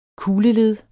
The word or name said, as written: kugleled